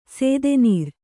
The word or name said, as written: ಸೇದೆನೀರು